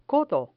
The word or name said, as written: codo